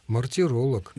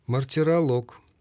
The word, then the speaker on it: мартиролог: Мартиролог. – Нет, мартиролог. Это ведь, да, древнегреческое ударение. Помните, физиолог, филолог, геолог и мартиролог до сих пор сохраняется в таком виде.